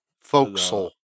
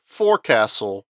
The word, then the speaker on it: forecastle: It's spelled forecastle. – Yes. – Pronounced forecastle. – Yes, you can pronounce it forecastle, but it's not the usual pronunciation of it.